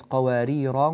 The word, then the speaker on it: قواريرا: كانت قواريرا قواريرا من فضة، هنا عندنا قواريرا، الراء هنا مرققة ومنونة، وإذا وقفنا عليها فإننا نثبت هذه الألف، كانت قواريرا، وإذا وصلنا كانت قواريرا قواريرا من فضة كما نطقتها الآن، كانت قواريرا قواريرا من فضة، فيعتنى بهذه الكلمة حتى ترقق الراء وتفخم الغنة بعدها، قواريرا قواريرا من فضة.